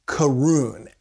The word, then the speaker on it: Caorunn: It's called Carun, and it's spelled C-A-O-R-U-N-N.